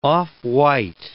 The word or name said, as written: off-white